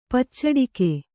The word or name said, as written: ಪಚ್ಚಡಕೆ